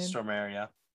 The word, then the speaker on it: Stormare: Stormare, yeah.